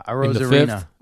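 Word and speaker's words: Arozarena: How do you say that other fellow's name? A Rosarino? – Yeah, a Rosarino.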